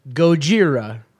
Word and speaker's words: Gojira: Or, as I prefer to pronounce it, Gojira. – Mm-hmm. – Because that's how it's pronounced.